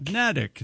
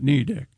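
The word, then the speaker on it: Neddick: It rhymes with medic, according to this. – Nedick. – Nedick, then, not Needick. – It sits on the small Nubble Island. – What? – Nubble. N-U-B-B-L-E.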